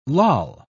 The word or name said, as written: loll